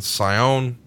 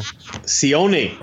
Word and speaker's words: Sione: Plus, we've got the bad guys, which is the 1-2-3 Kid, British Bulldog, Fatu, Razor Ramon, and Sione. I pronounced that all wrong. – Sione. – There you go.